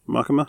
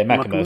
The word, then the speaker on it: Makima: Makima. – Yeah, Makima.